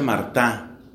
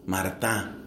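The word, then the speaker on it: Martin: El apellido de ellos era Martín, se escribe, se pronuncia Martá. Martá. Se escribe Martín o Martín.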